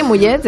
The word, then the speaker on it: mullet: Es el mullet, se pronuncia así, mullet, el peinado mullet.